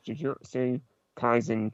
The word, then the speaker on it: Jujutsu Kaisen: Am I saying this right? – You to say. – Haven't I said it? – I've never seen it, but I know it's Jujutsu Kaisen Curse Clash.